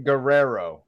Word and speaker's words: Guerrero: That is Dominic Guerrero. – Guerrero. – Yes, it is Dominic Guerrero.